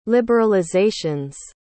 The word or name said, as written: liberalizations